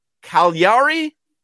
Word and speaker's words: Cagliari: Napoli host Cagliari. – Yeah, you got there. Wow. You got there. I spelt it phonetically for you. It's a GL. It's a GL, and people don't know what that means.